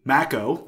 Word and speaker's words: Mako: He wrote, anyway, assuming this hasn't been covered, it's Mako, long A. So he's referencing Mako, the Joss Patel.